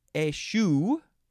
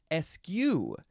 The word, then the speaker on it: eschew: And it can be pronounced a variety of ways. Eschew. Eschew. Bless you. And both of those, you can say the beginning as eh or ih.